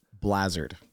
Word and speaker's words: Bleazard: Blazard. – Not Blizzard. Not Blizzard. Not, I don't know what else you've heard.